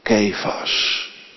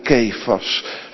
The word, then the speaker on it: Kefas: En ik zeg u, gij zult genaamd worden Kefas. Zo moeten we het eigenlijk uitspreken vanuit het Grieks. Gij zult genaamd worden Kefas, het welk overgezet wordt Petrus.